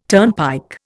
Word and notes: turnpike: Indian

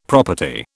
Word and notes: property: British English

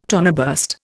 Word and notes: toneburst: Indian

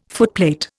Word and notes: footplate: Indian

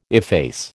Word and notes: efface: American English

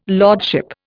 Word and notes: lordship: Indian

